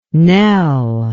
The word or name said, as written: Nell